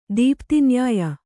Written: ದೀಪ್ತಿನ್ಯಾಯ

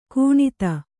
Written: ಗಣಿತ

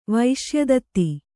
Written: ವೈಶ್ಯದತ್ತಿ

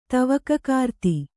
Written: ತವಕಕಾರ್ತಿ

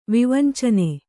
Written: ವಿವಂಚನೆ

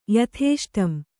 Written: ಯಥೇಷ್ಟಂ

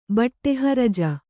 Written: ಬಟ್ಟೆಹರಜ

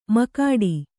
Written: ಮಕಾಡಿ

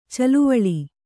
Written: ಛಲವೇಳು

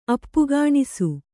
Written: ಅಪ್ಪಕಣಿಸು

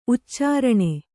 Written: ಉಚ್ಚಾರಣೆ

